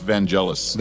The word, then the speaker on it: Vangelis: The music was put out by, was it Vangelis? Is that right? – Yes, Vangelis.